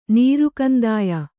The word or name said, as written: ನೀರುಕಂದಾಯ